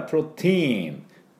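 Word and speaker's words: protein: ですからそこから来たんだと思いますが、発音がですね、多分ラテン系の人はこれプロテインとか読むんでしょうけど、英語の場合プロテイン、この TI は E って伸ばすだけなんです。プロテイン、プロテインっていうふうに発音します。プロテイン、テインと言いません、ティーンです。これだけ要注意。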